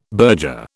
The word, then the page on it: burger: British English